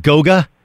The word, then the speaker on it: Goga: What about, what is it, how you pronounce it, Goga? – Yes.